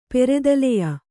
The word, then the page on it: ಪೆರೆದಲೆಯ: Kannada